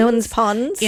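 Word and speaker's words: Ewens Ponds: I think it's Ian's Ponds or Ewan's Ponds. – Ewan's Ponds.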